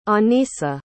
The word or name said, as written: Anisa